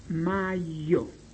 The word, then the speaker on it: majo: Ejemplo, mayo se escribe en esperanto majo, M-A-J-O, como le están oyendo. Majo se lee mayo, mayo.